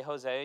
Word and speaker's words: Hosea: You pronounce it, the English pronunciation is the Hebrew pronunciation, so we don't have to work on that. You say Hosea, and you're saying it the way they say it in the Bible, and it means salvation.